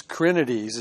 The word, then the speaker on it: Krenides: The name of the town then was called, and I think it's Krenides is the way it's pronounced, K-R-E-N-I-D-E-S.